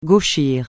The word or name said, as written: gauchir